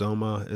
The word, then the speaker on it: Nzoma: So my name is Nzoma. It's spelled N like Nancy, Z-O-M-A, and that's from East Africa.